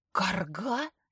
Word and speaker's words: Карго: «Карга? Серьезно? Прекрати!» Стреляет в меня смешливым взглядом. «Дед был академиком, очень уважаемым человеком. Это его фамилия. Ударение на первую «а», на конце «о».